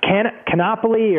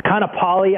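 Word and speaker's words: Kaanapali: And I think we're staying in a beach town called Can Canopoli, or I'm butchering Kanapali. I know I'm butchering it somewhere along the way, uh, but Maui will be the main home base for us for the better part of five or six days.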